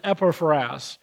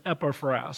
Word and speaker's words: Epaphras: But the right way to say it is Epaphras, according to my Logos click. I clicked on the word and it says Epaphras. So Epaphras. He was a servant.